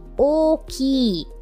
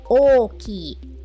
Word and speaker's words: 大きい: Our first word or first adjective, first E adjective is 大きい. O-O-ki-i. 大きい. So that is the word that means big or large. Okay. So notice that you pronounce it with a long O sound at the beginning.